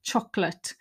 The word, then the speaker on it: chocolate: The adjectives have the weak ut form. So think about chocolate. It's one of my key words and also one of the most mispronounced words. It's on another list in the speaking planner. So many people say chocolate, chocolate, but it's chocolate, chocolate.